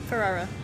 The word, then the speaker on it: Ferrara: Hey, Ryder, I'm saying your name wrong. Why am I not saying so many R's? I'm screwing it up. I want to say it in Spanish. Ferrara, right? – Yep, Ferrara.